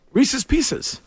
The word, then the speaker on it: Reese's Pieces: They created it to rhyme with pieces, not to rhyme with Reese's.